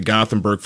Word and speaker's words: Gothenburg: Obviously, get the big win this past week over Mitchell, and defense has been such a strength of Gothenburg for years.